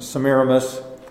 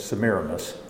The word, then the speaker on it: Semiramis: There's probably one more concerning Semiramis, if you know her by that name, but the actual pronunciation is Semiramis, and the effect of that religion on Israel after it began.